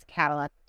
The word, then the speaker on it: Kadilak: So we're on social everywhere at Kadilak Homes, and it's Kadilak 2Ks. It's K-A-D-I-L-A-K.